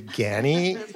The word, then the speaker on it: Ganni: I'll be damned if it's Ganni and not Ganni. I've been saying it wrong forever. But that's such Well, I asked that today because I had a meeting today, and it's like, it's officially Ganni.